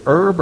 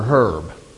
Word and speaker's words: herb: Is it herb or herb? If you're picking it out of the garden. If you meet them on the street, it's probably herb.